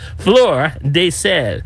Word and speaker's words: fleur de sel: Flora. – Actually, it's French, y'all. Fleur. I hope I'm saying it. I'm not butchering this. Fleur de Sel, which is Fleur.